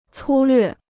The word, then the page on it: 粗略: cū llüè